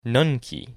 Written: Nunki

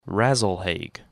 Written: Rasalhague

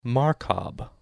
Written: Markab

